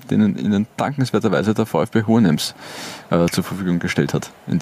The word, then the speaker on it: Hohenems: 72 Tore in 119 Partien für Hohenems. Habe ich das jetzt richtig ausgesprochen? – Ich hätte Hohenems gesagt. Aber, Hohenems.